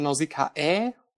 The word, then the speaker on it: Nausicaä: Also schreibt man Nausicaä, spricht man aber mit AA aus.